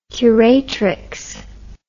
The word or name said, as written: curatrix